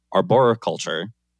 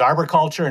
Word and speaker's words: arboriculture: arboriculture. How was my pronunciation on that? I've been practicing that one. – That's good. It goes both ways. Arboriculture and arboriculture.